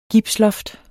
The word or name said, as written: gipsloft